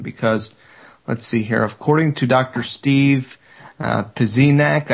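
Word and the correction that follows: Pieczenik: I can never say his name. – That's Steve Pichenik.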